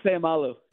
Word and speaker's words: Seumalo: Yeah, Isaac Seumalo.